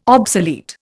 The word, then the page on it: obsolete: Indian